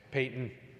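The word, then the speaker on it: Paton: Payton. It's not Patton, it's Payton.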